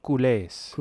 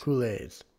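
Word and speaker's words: Culés: it's spelled C U L E S. Oh, Coulés. Coulés. Coulés.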